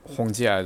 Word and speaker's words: Hongjia: Honggar is the I'm butchering the Cantonese pronunciation. Hongjia is the Mandarin pronunciation of those characters, but it's another style of Kung Fu, and that course matches up with earthbending from Avatar.